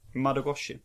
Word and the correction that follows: Nodogoshi: Nodogoshi. – Nodogoshi.